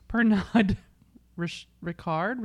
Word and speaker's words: Pernod Ricard: Uh, so, uh, this talks about so the, they interviewed a uh, an alcohol industry expert with past experience at Pernod Pernod I can never say this correctly Pernod Ricard, Richard. How, how are we saying this? – Jean Luc Picard.